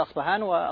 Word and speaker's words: أصفهان: هو أولا ولد في أصفهان، ولهذا ينسب إليها، تنطق بالباء وبالفاء، يقال أصفهان وأصفهان.